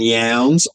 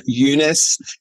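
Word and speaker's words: Younes: And we've been calling him Lincoln Yowns. I've been calling him that, and you've been pronouncing it, but mispronouncing it like me. But on the other night, I heard that the correct pronunciation for our great Australian actor is Lincoln Yunus.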